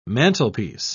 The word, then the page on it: mantlepiece: British English